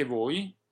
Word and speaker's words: voi: Esattamente, anche voi è un caso molto simile a mia, è un caso analogo. Voi, O e I sono separate, ma non vanno pronunciate distintamente. La O è abbastanza lunga e accentata. – Yes, voi is analogous to mia. The O and the I are pronounced distinctly, and the O is rather long and accented.